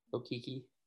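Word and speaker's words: Okeke: Okoke? Did I say that right? – Okiki.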